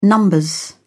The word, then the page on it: nos: British English